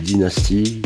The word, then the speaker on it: Dynasty: Alors, je m'appelle Daniel, sinon mon nom d'artiste c'est Dynasty, donc ça s'écrit D2E, plus loin N-A-S-T-Y.